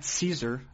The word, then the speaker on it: Szczur: Uh, Matt Szczur, I believe it's pronounced, and that's S Z C Z U R.